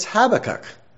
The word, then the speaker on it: Habakkuk: A fellow that's a little bit better at English than I am spoke to me at the back, and he said, there's only a few exceptions to the rule, but most of the time when there's a three-syllable name, the emphasis is on the first syllable. So that means we have been doing it right for all those years. It's Habakkuk. So if you put the emphasis there, it works out well.